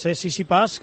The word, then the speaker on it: Tsitsipas: Luego nos hablas de la Davis, pero ha terminado el Masters en Londres y ha terminado con victoria de Tsitsipas ante Thiem en el tiebreak. – Bien pronunciado, además, Tsitsipas, que es como dice el que se dice,